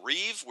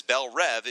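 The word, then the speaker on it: Belle Reve: He says, Bell Rev, it means beautiful dream in Creole French. It's meant to be Rev, not Reeve.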